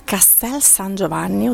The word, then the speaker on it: Castel San Giovanni: Siamo a Castel San Giovanni, ho detto giusto, esatto.